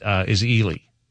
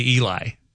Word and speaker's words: Ely: Another one they get wrong in Minnesota is Ely. They say Eli oftentimes.